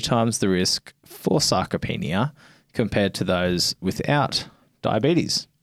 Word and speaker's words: sarcopenia: So recent research has found a strong link between diabetes and sarcopenia.